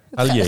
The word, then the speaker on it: Aliyev: Ja, Ilham Aliyev. Ik had het van tevoren uitgezocht hoe ik dit moest uitspreken. Maar Aliyev.